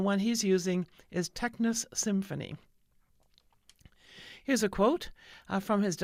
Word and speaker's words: Tecnis Symphony: Called a Tecnis Symphony, and I better spell that for you, Tecnis is T-E-C-N-I-S. The symphony part, I'm pronouncing it correctly, S-Y-M-F-O-N-Y, symphony.